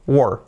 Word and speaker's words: were: Y este es uno, were. Were. ¿Dónde estabas? Where were you? Where were you? Así sería. So, esa es la primera palabra, el pasado verbo to be. Ya nunca más digáis where, vais a decir were.